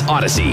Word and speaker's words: Audacy: A-U-D-A-C-Y. That's Odyssey. A-U-D-A-C-Y.